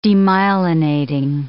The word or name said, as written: demyelinating